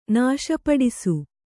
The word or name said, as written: ನಾಶಪಡಿಸು